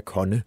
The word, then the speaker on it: Kone: Michel Nørgaard var jo inde på Kone, altså så, som også jo er elevator. Og som vist i øvrigt udtales Kone, kunne jeg sige, at der var nogen, der skrev.